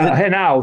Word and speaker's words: Henao: And how is it that you run across, am I pronouncing his last name correctly? Is it Hanau. Say Hanau.